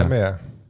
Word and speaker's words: Emmen: Ik wil dus zeggen, van het legendarische interview met Van Gastel in Emmen, zeg ik dat goed? – Ja, oh, ja. Emmen, ja.